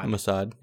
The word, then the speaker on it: Mossad: Canada recalls its ambassador to Israel after Mossad. Is that my pronouncing that right? – Mossad, yeah.